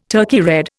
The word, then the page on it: Turkey red: Indian